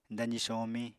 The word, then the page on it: dazi somiñ: apatani